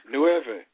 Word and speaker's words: Nueve: Nueve. – Try. – Nueve.